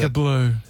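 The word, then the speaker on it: Baker Bleu: Baker Blair. Am I saying that correctly? – How do you spell it? – It's not Baker Blue, is it? – Baker Blue. – Is it Baker Blue? – Yeah, I think it's spelled B-L-E-U. – A bit of a French bit on it.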